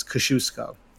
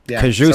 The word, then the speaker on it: Kosciuszko: But they were saying on the news how to say it. It's Kosciuszko. – Kosciuszko.